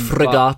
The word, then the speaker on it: frigate: It's pronounced frigate. – On a quick Google. Ah, frigate. Yeah.